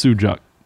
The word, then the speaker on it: sucuk: Yeah, it's sujuk. Spelled S-U-C-U-K, but it's pronounced sujuk.